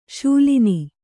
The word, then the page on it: ಶಾಲೀನ: śalīna